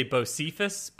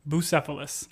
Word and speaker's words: Bucephalus: I want to say Bocephus, but that's not right. That's like a country song. So mm-hmm. Bucephalus.